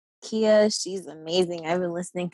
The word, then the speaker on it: Keiyaa: Kia. So it's spelled K-E-I-Y-A-A.